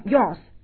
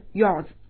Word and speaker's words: yours: 那么同样我们在 your 的后面加了一个 s，那么注意读音也不要读成 yours，而是 yours 啊，这一点大家要注意。